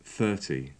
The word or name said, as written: thirty